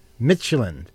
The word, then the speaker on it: Midtjylland: Our team is, how do you say it? – Michelin. – Okay, the Danish team. MIDTJYLLAND. That's why I have trouble pronouncing it, because that's how it's spelled.